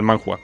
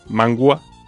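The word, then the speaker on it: Manhwa: Bueno, el manhua, mejor dicho.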